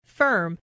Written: firm

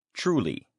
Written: truly

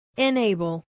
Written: enable

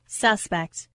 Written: suspect

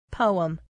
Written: poem

